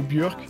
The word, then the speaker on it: Björk: Et s'il y a une figure qui a grandement marqué le monde de la musique dans ce domaine, c'est Björk, ou Björk si on le prononce correctement, mais j'ai prononcé Björk.